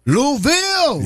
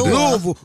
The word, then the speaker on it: Louisville: Louisville. – Yeah, what's happening? – You said Louis. You know, they'll cut you out by that. – Louisville.